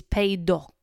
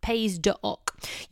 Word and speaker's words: Pays d'Oc: What you want to look for on a label is Pays d'Oc. So it's spelt Pays with an S on the end, D apostrophe O-C, Pays d'Oc.